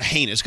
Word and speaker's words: heinous: And I love the word heinous because it rhymes with anus, and who doesn't, you know.